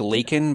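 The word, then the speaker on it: Laken: It was like Lakin, but he called him Lincoln.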